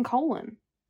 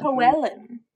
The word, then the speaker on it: Coelen: C-O-E-L-E-N. How do you say that? – C-O-E-L-E-N. – C-O-E-L-E-N. How do you say that without saying colon? – No, it wouldn't be colon. – Coelin.